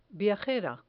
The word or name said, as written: viajera